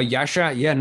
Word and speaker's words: Jasar: Now, Jasar, nice there with the pronunciation of the J properly there, GB.